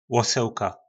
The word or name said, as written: loselka